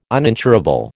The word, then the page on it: uninsurable: American English